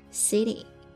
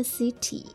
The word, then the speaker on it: city: 那其实呢，在美音里面 ty 这种结尾呢，经常会有一点点像 dy 的发音，但是呢，又是在介于 t 和 d 之间的 city city，不要说 city，也不是 city，而是 city city。